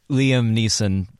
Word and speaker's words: Liam Neeson: liam neeson um, would did I say his name right? – Yeah.